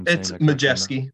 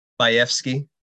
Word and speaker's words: Majewski: Hopefully, Majewski. Yeah, silent W. – Silent W. You don't see very many silent W. I guess you do. – Yeah, it's actually a Polish name. I never pronounce it the correct Polish pronunciation anyway. So what's the Polish pronunciation? – Bajewski.